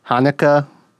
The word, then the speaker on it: Haneke: I believe his name is pronounced Mikkel Haneke. I tried to look up pronunciation before I started this episode, and I think that's roughly how you say it.